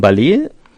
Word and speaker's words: Ballet: Das ist von Ballet, heißt die Band, und reimt sich auf Array. – Ja, weil das anders ausgesprochen, das ist ja französisch ausgesprochen.